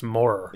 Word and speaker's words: Mauer: No, it's spelled Mauer, but it's pronounced more. It is more.